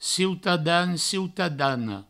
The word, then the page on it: ciutadan, ciutadana: cieutadan, cieutadano; provençal